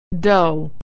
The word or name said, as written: dough